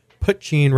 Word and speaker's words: Poitín: I'm joined this week by Ian Tucker and Dominick Lee from Poitín Restaurant, newly opened in Sawyer Yards.